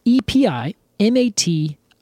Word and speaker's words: epimatium: I can't really find a good way to pronounce this. I can tell you exactly what it is, but gosh dang it, nobody has taught me how to say this word. It's epimatium.